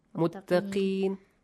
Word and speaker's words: متقين: When it has a كسرة, do not pronounce O sound. Okay. متقين.